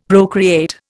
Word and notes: procreate: Indian

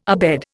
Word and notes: abed: Indian

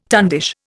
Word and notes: tundish: Indian

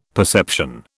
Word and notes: perception: British English

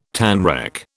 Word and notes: tanrec: British English

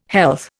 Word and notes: health: Indian

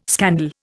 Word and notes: scandal: Indian

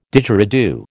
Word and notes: Didgeridoo: American English

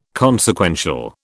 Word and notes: consequential: British English

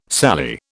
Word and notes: sallee: British English

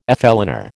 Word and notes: flâneur: American English

